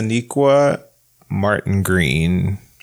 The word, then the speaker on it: Sonequa Martin-Green: Yes, yeah, Sonequa Martin-Green.